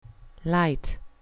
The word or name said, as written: light